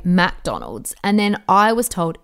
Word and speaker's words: McDonald's: It's McDonald's, not MacDonald's.